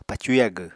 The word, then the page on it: pacu yagw: Apatani